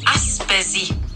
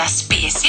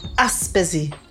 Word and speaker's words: Aspesi: Tänkte bara tala om att det heter Aspesi med betoningen på och inte Aspesi.